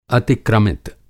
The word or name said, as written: अतिक्रमित